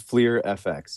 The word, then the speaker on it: FLIR FX: It is FLIR FX. Yeah. – FLIR FX. Okay. – Like FLIR, like the baseball cards, basically.